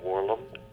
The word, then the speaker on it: Warlum: My name is Michael Warlam, W-A-R-L-U-M.